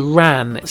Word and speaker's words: wren: And also in that one, and I should have mentioned this earlier, in Ireland they refer to the wren as the ran. So it's spelt W-R-A-N rather than W-R-E-N.